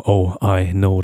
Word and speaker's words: Oino: Alles zusammengeschrieben und auch nicht wirklich in Englisch, sondern mehr so in Lautschrift geschrieben. Dann wird es so wie Oino ausgesprochen. Oh I Know.